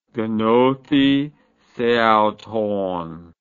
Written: gnothiseauton

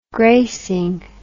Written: greycing

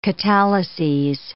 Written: catalyses

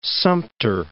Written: Fort Sumter National Monument

